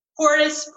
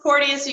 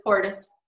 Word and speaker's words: Porteous: Portis, Porteous. You can say it however you want, but it's Portis.